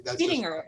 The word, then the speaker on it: Biddinger: It's actually silent. I say Biddinger, but that's Biddinger. – Just... – Oh, okay. There's no G.